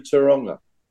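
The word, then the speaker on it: Tauranga: Because the last time I was in Taranga, that's how you pronounce it, by the way, Smithy, I had a party of a lifetime.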